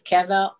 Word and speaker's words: Caviezel: Caviezel. – Caviezel.